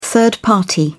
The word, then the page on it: third party: British English